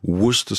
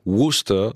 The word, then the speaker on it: Worcester: Worcester Sauce.